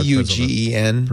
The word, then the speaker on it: Eugen: Okay, yeah, Eugen. – Pronunciation. – Yeah. – What if we're German? It'd be Eugen. – He's German, so it's probably what it is then. I think he's Eugen.